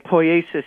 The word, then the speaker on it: poiesis: Well, that skill, in fact, brings us to, maybe you can help me with the pronunciation here again, Sean Kelly, poiesis? – Poiesis, yes.